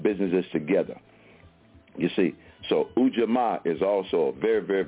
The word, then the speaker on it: Ujamaa: And that's spelled U-J-A-M-A-A. Again, U-J-A-M-A-A, Ujamaa.